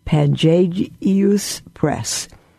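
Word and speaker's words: Pangaeus: It's P for Peter, P-A-N for Nancy, G-A-E-U-S. Pangagius Press.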